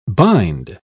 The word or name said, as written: bind